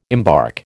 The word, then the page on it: embark: American English